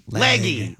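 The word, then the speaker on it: Legge: Catherine Leggy. – Leggy. – Leg. Hobie corrected you last time. It's pronounced Leg.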